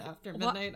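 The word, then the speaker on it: AFTRMDNGHT: It's spelled a little funny. It's A-F-T-R-M-D-N-G-H-T. So it's After Midnight, but the only vowel included in that is the A.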